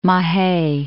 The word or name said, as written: Mahe